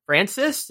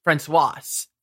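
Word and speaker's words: François: The movie was directed by Steven Spielberg and stars Richard Dreyfuss, Terry Garr, Melinda Dillon, Francis? Is that? – Francois. – Thank you. – Well, actually, it's French, so it's Francois.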